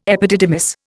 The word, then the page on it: epididymis: Indian